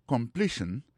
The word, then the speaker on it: completion: Nan pou komanse ankor, sentence, sa vle di phrase, aksan tombe sou premye silab la, completion, sa vle di complété, fason yo complété, ouan? Accent tombé sous deuxième syllabe là.